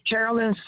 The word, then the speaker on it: Carolyn: Is Carolyn, Carolyn. – C-O-R. – C-A-R-O-L-Y-N. And Colin, C-O-L-L-I-N.